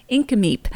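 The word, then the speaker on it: Nk'Mip: One of the newest is Nemmick Cellars, pronounced Inkameep, North America's first winery owned and operated by Aboriginals, the Osoyoos Indian Band.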